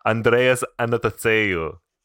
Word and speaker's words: Andreas Anastasiou: I don't actually know how to say it. Something like that. – It's Andreas Anastasiou.